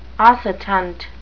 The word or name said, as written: oscitant